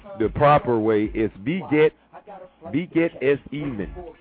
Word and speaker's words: wie geht es Ihnen: W-I-E, and the W is always pronounced V. So it's beget, G-E-H-T, space E-S, space I-H-N-E-N, question mark, which is how are you, formal way of saying how are you. Beget es even. Beget es even.